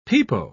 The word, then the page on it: pepo: British English